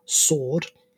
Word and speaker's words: SWORD: Arisaka is put in charge of Class A, the Social Ops Research and Development, or SWORD, just without the silent W, class, which takes on private sector contracts.